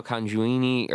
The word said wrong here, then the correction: Nanjiani: Kumail Nanjiani.